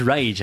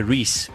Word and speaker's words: Reis: Actually, because when I first saw it, I wanted to say Reese, but then I heard it. It's spelled sort of Reese. R-E-I-S.